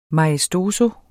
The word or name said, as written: maestoso